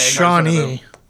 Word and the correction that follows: Chaney: Chaney is how it's said in the... – Yeah, in book. The, well, that's in the audiobook, okay, audiobook okay. It was Chaney.